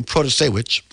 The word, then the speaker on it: Protasiewicz: So yes, Judge Janet Protasiewicz, and that's how you say her name, Protasiewicz.